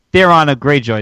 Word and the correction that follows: Theon: Did I say his name correctly? – Theon Greyjoy? – Oh, Theon Greyjoy? – Theon Greyjoy, yes.